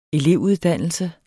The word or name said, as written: elevuddannelse